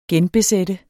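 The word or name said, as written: genbesættes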